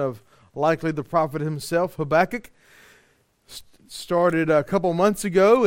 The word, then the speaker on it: Habakkuk: Habakkuk, Habakkuk, Habakkuk, whichever way you want to say it. We do believe it is best pronounced Habakkuk.